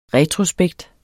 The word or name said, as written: retrospekt